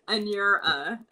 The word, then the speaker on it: Anura: I would write down ANYOUR, like your, and then -A, so that people can see how it's phonetically said. Anura.